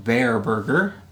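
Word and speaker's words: Bare Burger: And then after that, we went to a burger place called Bear Burger. It's spelled B-A-R-E.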